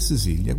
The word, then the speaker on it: Cecilia: Io leggevo chiaramente Cecilia, come no, nella nostra lingua, ma giustamente in inglese si pronuncia in un altro modo, e Sicilia.